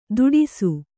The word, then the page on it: ತುಟಿಸು: kannada